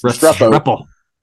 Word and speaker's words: Restrepo: Restrepo.